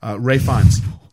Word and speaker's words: Ralph Fiennes: Ralph Fiennes. Ralph Fiennes. – Don't call him Ralph, even though it's spelt that way. It's Ray for some reason.